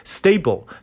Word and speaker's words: stable: 这个单词其实很简单，叫 staple，s t a b l e，staple，staple。啊、 uh,，excuse me，啊、uh,，发音有点问题。 Stable，alright，S T A B L E，stable，刚才读成了这个订书机的词了。 Stable，那本身 stable 作为形容词来讲呢，是稳定的一层意思。